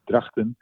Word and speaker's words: Drachten: There is in Dutch, there is this guttural G, which hurts the throat a little bit, you know, the G. So it's Drachten, but it's good.